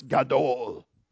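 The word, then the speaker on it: Gadol (גדול): It's Gadol. And you have to say it like that.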